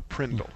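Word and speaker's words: PRNDL: If you pronounce that phonetically, it's called the Prindle.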